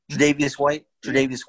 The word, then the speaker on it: Tre'Davious: What's his – Javarius White? Is that how you say his name? Tre'Davious White.